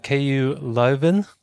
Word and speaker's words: KU Leuven: Today we have Claudia Diaz from KU Leuven.